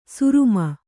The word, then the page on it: ಸುರುಮ: suruma